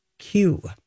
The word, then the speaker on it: queue: And Q is a great word because it's the actual important letter, Q, and then four more silently waiting behind in line. Because it's spelled Q-U-E-U-E. But you just call it Q.